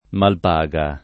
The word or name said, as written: Malpaga